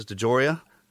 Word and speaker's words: DeJoria: And today, on as our guest, man, we have Alexis DeJoria.